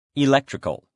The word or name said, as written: electrical